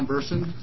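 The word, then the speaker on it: Bersin: My name is Ron Burson. Last name is spelled B as in boy, E-R-S-I-N.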